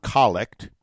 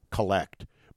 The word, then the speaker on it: collect: And he says the term collect, and he says, remember the accent is on the first syllable in this context, not collect, but collect, refers to a collecting of the petitions of the congregation into one prayer.